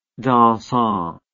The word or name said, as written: dansant